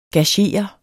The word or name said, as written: gagere